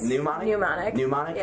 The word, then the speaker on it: mnemonic: Mnemonic? – Yeah. – So the M in front of the N is silent? – Yeah.